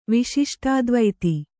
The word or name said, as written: ವಿಶಿಷ್ಟಾದ್ವೈತಿ